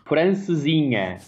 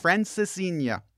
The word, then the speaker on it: Francesinha: When I was in Lisbon, I actually saw this sandwich, and I'm going to try and pronounce it Francesquina. – Francesinha. – Francesinha.